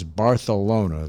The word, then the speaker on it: Barcelona: And they came after Sophie Tucker's Barcelona, the proper Cantillon pronunciation of that city.